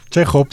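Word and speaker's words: Chéjov: Chejov.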